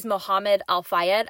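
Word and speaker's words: Mohamed Al-Fayed: And he claimed, this is Mohammed Al-Fayed.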